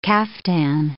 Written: caftan

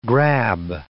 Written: grab

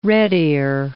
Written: redear

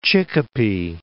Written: Chicopee

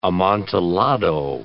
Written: amontillado

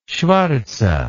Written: schvartze

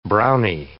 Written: browny

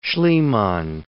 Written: Schliemann

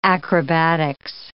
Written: acrobatics

